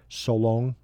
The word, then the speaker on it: Solong: Just like Solong, I went the other way. Solong, uh, that's how you pronounce it.